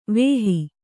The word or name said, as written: ವಾಹಿ